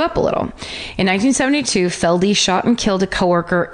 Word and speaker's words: Felde: His name is Wayne Robert Feld, pronounced Feldy. I couldn't know until I got to that part of the parentheses. – F-E-L-D? – F-E-L-D-E. – Feldy.